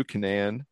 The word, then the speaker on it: Canaan: So, according to police reports, Mr. Rumble Johnson, he was arrested by the New Canaan. Am I saying that right? Canaan? – That's how I would say it, yeah.